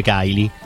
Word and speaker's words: Kylie: Quindi col KY si legge Kylie.